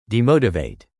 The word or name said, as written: demotivate